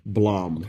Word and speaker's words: BLAME!: So it's spelled Blame, but in the trailer, they pronounce it Blom.